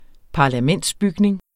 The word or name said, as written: parlamentsbygning